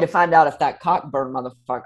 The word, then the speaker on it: Cockburn: Well, it's spelled Cockburn, but he goes by Coburn for obvious reasons.